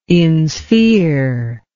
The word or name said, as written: insphere